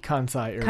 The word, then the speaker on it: Kansai Airport: Kansai Airport.